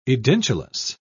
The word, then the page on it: edentulous: British English